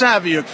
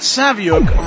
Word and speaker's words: Saviuk: Hello, this is Alex Saviuk for 11 O'Clock Comics, and that Saviuk rhymes with book.